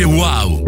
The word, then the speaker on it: UAU: Si scrive UAU, si legge wow.